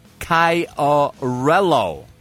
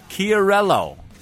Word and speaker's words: Chiarello: It's pronounced Chiarello, said smoothly. So let me try that again. I'm sorry, it's not Chi, it's Key. – So you fuck it up. – Chiarello, said smoothly.